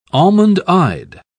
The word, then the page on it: almond-eyed: British English